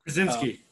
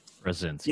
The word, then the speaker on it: Krasinski: Krasinski. – Krasinski, yes.